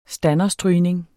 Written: standerstrygning

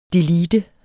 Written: delete

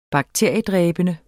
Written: bakteriedræbende

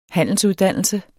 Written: handelsuddannelse

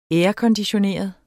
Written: airconditioneret

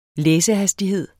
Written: læsehastighed

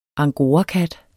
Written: angorakat

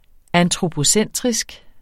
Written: antropocentrisk

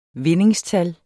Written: vindingstal

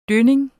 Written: dønning

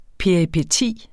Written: peripeti

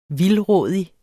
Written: vildrådig

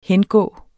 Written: hengå

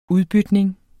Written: udbytning

